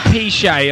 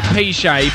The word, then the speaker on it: Peshay: Sorry, mate, it's just the way it's spelled. P-Shay, alright? P-Shay. P-E-S-H-A-Y.